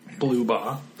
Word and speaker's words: Blubaugh: Blubaugh. – Blubaugh. – Like, the color and then the sound a goat makes.